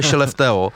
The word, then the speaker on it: Skellefteå: píše se to Skellefteå a vyslovuje Skellefteå, a tam říkali, že tak základní skupina nic moc, ale s přibývajícím playoff ty lidi jako zbystří a začnou chodit, no.